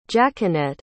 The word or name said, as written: jacquenette